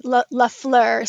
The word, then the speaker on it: Lafleur: Lafleur.